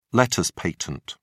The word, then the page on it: letters patent: British English